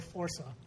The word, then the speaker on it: Forza: Is it Forza or Forza? – Forza. – Forza. – Forza. Okay, because I've heard people pronouncing it in different ways. It's like I think I've mostly heard Italians pronouncing it Forza.